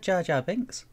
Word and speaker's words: Jar Jar Binks: Yeah, I think you said Jar Bar Jinx. – Bar Jar Jinx. I didn't say I said Jar Jar Binks.